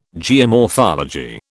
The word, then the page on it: geomorphology: British English